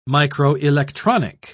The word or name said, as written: microelectronic